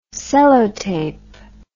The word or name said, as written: Sellotape